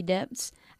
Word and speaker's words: debts: La B debts. Es muda. Y debts. La E se pronuncia como una E.